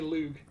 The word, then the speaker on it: Lugh: Now, you're going to have to forgive me if you have any knowledge of Irish or old Irish pronunciation, but I'm going to say Lug. The god L-U-G-H, whence we get the term Lunasa for the second harvest.